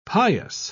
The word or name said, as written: pious